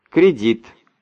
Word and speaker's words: кредит: Кредит. Заем суда. Некоторые суммы денег, предоставленные на срок за определенную плату. Судный процент. Параграфы 8.2.8.3. Курсивом. Бухгалтерский кредит. Ударение на первом слоге.